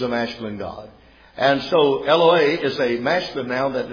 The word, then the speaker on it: Eloah: Though it is the plural of Eloah, E-L-O-A-H, that's how we would pronounce it out in English, at least the way it is, I believe, in Strong's Concordance.